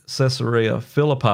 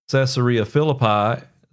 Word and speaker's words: Caesarea Philippi: And of course, it's only mentioned in Matthew's Gospel when he said to at Caesarea Philippi, I'll say it right in a minute, Caesarea Philippi, Simon Peter,